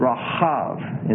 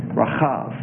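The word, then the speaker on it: Rahav (רחב): Whereas Rahab of Jericho is a broader vowel sound, and it's Rahav, and it's the guttural chet, Rahav.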